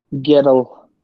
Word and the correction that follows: gwrol: It's Gural, and it means brave,